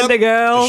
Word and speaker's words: Senegal: I can never pronounce their name. I'm like, Senegal. – There's no D. – I know, it just, the D shows up. – The D always shows up. – Senegal.